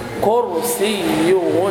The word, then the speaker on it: كرسيه: وسع، خطأ أن تقول وسع، هذا لحن منتشر، وسع، وسع كرسيه، لا تقل كرسيه، كرسي، كر، كر، لأن الراء مفخمة مسبوقة بحرف الكاف والكاف مضمومة، الراء الساكنة تأخذ حكم ما قبلها، فنقول كر، كر، ولا تقل قر، قر، خلاص بنطق الكاف قافا، فلا تقل كرسيه، لكن كر، كر، كر،